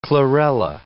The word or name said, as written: chlorella